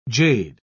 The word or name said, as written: jade